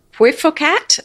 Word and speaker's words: Puiforcat: and the cat is a P U I F O R C A T.